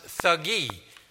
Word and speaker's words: Thuggee: We would pronounce it Thuggee, where we get the word thug from. The Thuggees were an extreme fanatical cult within Hinduism, worshiping the goddess Kali, K-A-L-I.